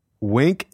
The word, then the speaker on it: Winc: Wink is spelled W-I-N-C, and it makes it easy to discover great wine.